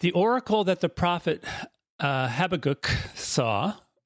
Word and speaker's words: Habakkuk: Habakkuk is the traditional American English pronunciation of it.